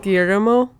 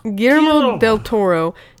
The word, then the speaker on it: Guillermo del Toro: Guillermo.